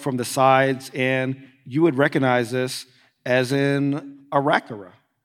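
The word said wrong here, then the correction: Aarakocra: Am I mispronouncing that? – An Arakocra. – Arakocra. That's what I said.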